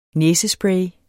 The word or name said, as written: næsespray